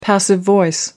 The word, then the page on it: passive voice: British English